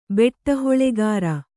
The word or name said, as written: ಬೆಟ್ಟಹೊಲೆಗಾರ